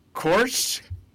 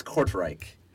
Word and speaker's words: Kortrijk: His team? Korch? Korch, maybe? – I believe it's Kortrijk. I believe that it's a Dutch word, like the Dutch side of Belgium.